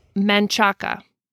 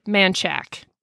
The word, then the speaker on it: Manchaca: There's one that is Manchaca. That's how it looks. That's how it's pronounced. Manchac. In what? Texas, it's Manchac.